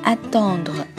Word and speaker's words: attendre: 今天呢，要给大家介绍一个单词，这单词读作 attendre。